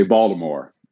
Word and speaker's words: Baltimore: People from Baltimore say Baltimore, as if there's a D.